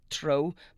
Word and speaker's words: Treo: Trio is actually an Irish word, T-R-E-O. It's supposed to be pronounced tro, but nobody would ever get it, so we don't mind it just being called Trio.